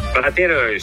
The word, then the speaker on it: Platero: Arriverai perché questa sera ci sarà un grande spettacolo alla Roccaflea di Gualdo Tadino alle ore 21:30 a Platero Io. Ho detto bene? – Platero, sì, è un libretto bucolico molto famoso in Spagna.